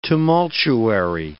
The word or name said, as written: tumultuary